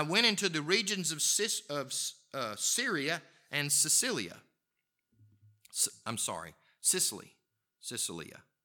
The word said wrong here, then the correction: Cilicia: I can't say it correctly. Yeah, say it for me, somebody. Cilicia, thank you. I don't know why I was struggling with that. I was trying to add a bunch of other syllables, which maybe that's a southern thing.